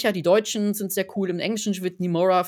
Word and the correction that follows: Nimona: von ähm, Nimona heißt sie so.